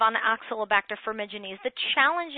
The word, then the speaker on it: Oxalobacter formigenes: So I want to go back to the Oxalobacter formigenes.